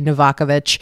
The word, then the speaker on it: Novakovic: My last name, like, it's Croatian. If I pronounced it correctly, it would be Novakovic.